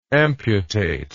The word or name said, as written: amputative